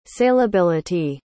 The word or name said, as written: saleability